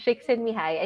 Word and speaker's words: Csikszentmihalyi: So it's a very difficult name to spell. Yes. But that's how you pronounce it, Dr. Mihai Csikszentmihalyi, and he is a Hungarian-American psychologist.